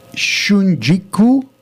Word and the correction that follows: shungiku: How do you pronounce it? – Shungiku. – Shungiku.